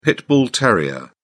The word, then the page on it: pit bull terrier: British English